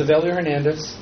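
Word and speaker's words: Evelio Hernandez: My name is Avilio Hernandez.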